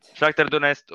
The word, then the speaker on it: Shakhtar Donetsk: Shakhtar Donetsk. Shakhtar Donetsk och Celtic.